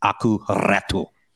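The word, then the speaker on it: Aku Räty: This is just for Major Nelson, so that he knows that I've cemented in my brain the correct pronunciation. It's Aku Ratu.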